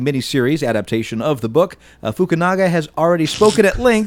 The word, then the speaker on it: Fukunaga: Your Japanese syllables. Read what actually the name is. – It's Fukunaga. – It's Fukunaga.